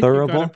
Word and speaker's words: thurible: The thurible, and correct me if I'm mispronouncing that. The no, I think thurible, pretty good.